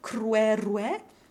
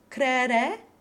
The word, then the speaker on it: croiroit: As we saw here in cruerue, C-R-O-I-R-O-I-T, we have the choice of saying cruerue, with W open E in both syllables, crere, with no W, just the open E, or cruere, which we would phonetically spell as K-R-W epsilon R epsilon, only using the W in the first syllable.